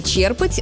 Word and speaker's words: черпать: У глагола «черпать» ударение устойчивое, оно сохраняется и в других формах – «черпаю», «черпаешь», «черпают», «черпал». Само слово знакомое. Можно черпать воду из ведра, а можно черпать вдохновение или знание. Чтобы запомнить, помогает простая мысль. Если вы что-то берете из глубины, ударение тоже остается в основе.